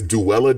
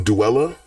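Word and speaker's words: Duela: They have the people in it are Dula. I'm sorry, did you know that Dula Dent's name is Duella Dent? Her name is pronounced Duella.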